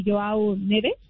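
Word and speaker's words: João Neves: Pero más se desató por las declaraciones que dio precisamente Joao Neves, si lo pronuncié bien.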